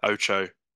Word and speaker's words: Ocho: Is it Ocho? – Yeah, Ocho.